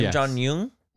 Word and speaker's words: Kim Jong Un: But Kim Jong Un. – Uh-huh. – Am I saying the name correctly? – Kim Jong Un.